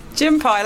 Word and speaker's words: Gympie: Is that how you pronounce it? Gympie or Gympie? – Gympie, let's go. – I'm going to pronounce it Gympie because it's spelled Gympie.